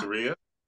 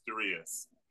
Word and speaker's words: Darius: you know, my name is spelled like Darius, but it's pronounced Daria.